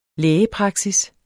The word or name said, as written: lægepraksis